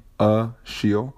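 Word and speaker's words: Rachille: So for Instagram, which is what I use more often, my handle is at rashiel. Now, that's how you pronounce my name, Ashiel. Fit, okay? So that's R-A-C-H-I-L-L-E, fit, all right?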